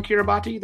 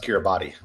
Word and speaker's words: Kiribati: Is there a volcano on Kiribati that's going to be part of the tale? – No, and it's not pronounced Kiribati.